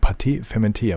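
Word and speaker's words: pâte fermentée: Im Zweifel ist das dann eben ein Vorteig oder beziehungsweise ein Pâté fermenté.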